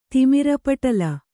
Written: ತಿಮಿರಪಟಲ

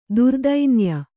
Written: ದುರ್ದೈನ್ಯ

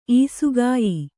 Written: ಈಸುಕಾಯಿ

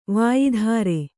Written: ವಾಯಿಧಾರೆ

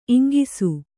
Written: ಇಂಗಿಸು

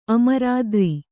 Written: ಅಮರತರು

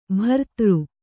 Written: ಬಿರಾದರ

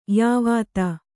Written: ಯವತಿ

